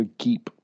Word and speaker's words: geep: They call it a geep. – They call it a geep? – Yep. – That's too close to gimp. And sounds a little like Jeep without the J, but a hard G.